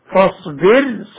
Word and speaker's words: فاصبر: مثال ذلك كما ننطق بها مرققة هكذا فاصبر صبرا جميلا، ولا تصعر خدك، أن أنذر قومك.